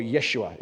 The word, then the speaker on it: Yeshua: Or Yeshua, if you're going to say it properly.